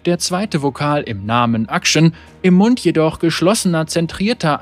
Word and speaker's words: Akshan: Akshan wird Action ausgesprochen. Der erste Vokal wird im amerikanischen Englisch nur selten benutzt, ist in den restlichen Teilen der Welt jedoch weit verbreitet.